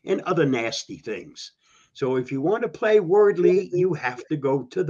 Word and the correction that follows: Wordle: It's called, I think it's pronounced Wordle. – But yeah, well, yeah, correct. – But, Wordle is correct.